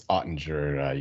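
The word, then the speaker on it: Oettinger: Good pronunciation. It is Ottinger.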